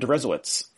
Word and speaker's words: Deresiewicz: I'm your host, and my guest today is William Deresiewicz.